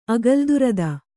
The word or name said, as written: ಅಗಲ್ದುರದ